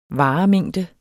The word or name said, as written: varemængde